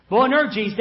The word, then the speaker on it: Boanerges: Boanerges, that is, sons of thunder.